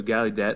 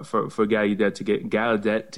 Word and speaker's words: Gallaudet: But we, you know, Gallaudet, you do have a Gallaudet. I'm going to start pronouncing it right, since you used to work there. You must know how to pronounce it. I'll take the U out of it.